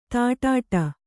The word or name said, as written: ದಡ್ಡಿ